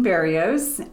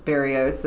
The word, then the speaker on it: Barrios: Well, Aileen Barrios, and uh, rhymes with Cheerios, Barrios.